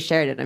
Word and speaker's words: Sheridan: Hi, my name is Sheridan. I'm so very glad to be here. Big fan. – Sheridan? – Yes. – Like the hotel? – Spelled differently, spelled better. But yes, same pronunciation.